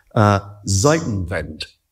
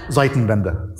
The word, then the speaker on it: Zeitenwende: Zeitenwende, which I probably mispronounced. – You did. – Thank you. How would I pronounce it properly? – Zeitenwende.